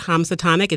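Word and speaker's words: Khamsatonic: Instagram is at Hamsatonic. It's spelled K-H-A-M-S-A tonic.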